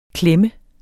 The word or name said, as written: klemme